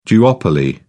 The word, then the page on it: duopoly: British English